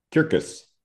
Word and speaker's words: Cercis: He's been breeding and selecting redbuds, which is the genus Cercis, C-E-R-C-I-S. Interesting to note, I took 2 years of Latin in junior high school. There is no soft C in Latin. So if you were a Roman, you would pronounce that Circus. But we say Cercis.